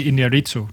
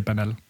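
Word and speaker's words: Iñárritu: Og for øvrigt, det, det er Inarito, du prøver at sige, når du siger banal.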